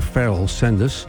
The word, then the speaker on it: Pharaoh Sanders: Je schrijft het Pharaoh A, maar je moet uitspreken Farrell Sanders.